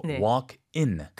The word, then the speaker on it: walk-in: 근데 그걸 walk-in이라고 해서 IN을 해야 되는데 그 ING로 발음하시는 경우가 은근히 많더라고요. – 원래 스펠링이 walk하고 이제 dash IN이잖아요. 그렇죠? 근데 빨리 발음하면 walking처럼 들릴 수 있어요. – 그럴 수 있어요. – 근데 walking이 아니고 walk-in.